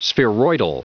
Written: spheroidal

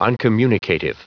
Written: uncommunicative

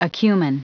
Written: acumen

